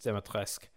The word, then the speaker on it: Dimitrescu: Her surname, Dimitrescu, is pronounced Dimitrescu. And it sounds kind of French to me. The way it's spelled is the Romanian spelling of a Greek name.